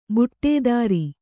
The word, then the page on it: ಬುಟೇದಾರಿ: buṭēdāri